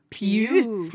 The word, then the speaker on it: Pugh: Hvordan uttaler man dette navnet? Pew. Pew.